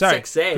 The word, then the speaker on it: Sexay: I have no swag in comparison to a man like Grandmaster Sexay. – No, you don't. But then Lowdown came out. – Hold on, is the correct phrasing Grandmaster or Master? – Grandmaster Sexy. – Okay. I know it's Sexay.